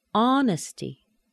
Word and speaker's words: honesty: Notice the TY is a shortened vowel sound, but it's a weak syllable. Honesty.